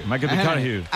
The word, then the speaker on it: McConaughey: Hay que ir corrigiendo la pronunciación. – McConaughey.